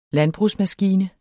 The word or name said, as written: LANDBRUGSMASKINE